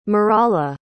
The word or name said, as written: maralah